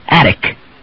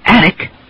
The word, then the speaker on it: attic: But attic has the first syllable stressed, and we use the D substitution, attic.